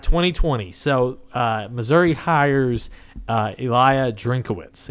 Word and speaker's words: Drinkwitz: Is it's like is you it, think it'd be Drinkwitz. But it's not. – It's from all that I've heard pronounced, it's Drinkwitz.